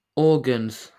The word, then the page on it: organs: British English